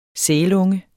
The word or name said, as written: sælunge